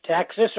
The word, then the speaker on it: Taxus: Taxes are growing all over the world, and there's many, many varieties. – Yep. – And it's pronounced taxes, like mm-hmm. taxidermy.